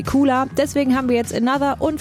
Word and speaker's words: ANOTR: Geschrieben A-N-O-T-R, ausgesprochen Another. Ist ein DJ-Duo aus Amsterdam, haben sich lustig geschrieben, heißen eigentlich Jesse oder Jesse van der Heijden und Oguzhan Güney.